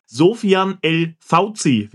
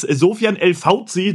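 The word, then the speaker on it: Soufiane El-Faouzi: Genau, und es ist Sofyan El Fauzi, das vielleicht mal an alle, die dafür ein kleines Herz haben für Namen.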